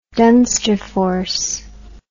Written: Dunsterforce

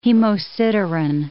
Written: hemosiderin

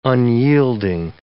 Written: unyielding

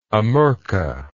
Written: amurca

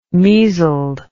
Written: measled

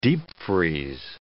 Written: deep freeze

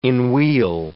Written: enwheel

